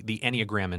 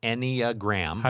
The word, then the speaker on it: Enneagram: Don Hudson, he was the creator of this place, the Enneagram Institute. – What was that? – Enneagram. – How do you spell that? – E-N-N-E-A-G-R-A-M.